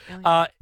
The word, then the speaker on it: Ilya: Anyway, she has revealed that she has named her newborn son Ilya. Am I saying that right? Ilya? Yeah. – Ilya.